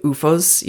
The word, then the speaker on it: OOFOS: It's like they have, it's spelled OOFOS. – Recovery footwear? – Yeah. It's UFOS is a recovery footwear brand.